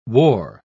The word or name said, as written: wore